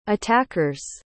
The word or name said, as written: attacker's